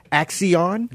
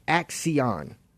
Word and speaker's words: Axion: Is spelled A as in apple, C as in cat, C as in cat, I, O, N as in Nancy. Axion.